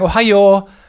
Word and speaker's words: Ohio: Aber alle sagen Ohio. Also um, so, äh, es fließt so aus. So tief, hoch, tief. Es Ohio.